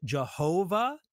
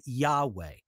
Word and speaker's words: Yahweh: Some people have pronounced it Jehovah in older things, but really it's probably more accurately translated Yahweh.